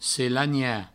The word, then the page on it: selanhar: selagna